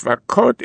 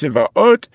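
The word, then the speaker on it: Tzvaot (צבאות): Rabbi Yossi Omer, as far as I'm concerned, that name that you keep listing of Tzvakot is not to be pronounced Tzvakot, it's pronounced Tzvaot,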